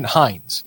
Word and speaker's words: Heinze: They're looking at a new head coach, and the new head coach is rumored to be Gabriel, on the pronunciation, Heinz, H-E-I-N-Z-E.